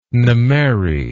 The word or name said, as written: Nimeiry